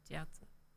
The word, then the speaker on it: Jiaozi: Yeah, Jiaozi.